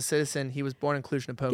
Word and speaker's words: Cluj-Napoca: I can't even pronounce the name of this city. So guys, Cluj-Napoca. – Cluj-Napoca. – No, Cluj-Napoca.